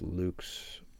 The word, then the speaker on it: Lux: Luke, it's Lux, L-U-X, but I think it's pronounced Luke's, Sun Luke's.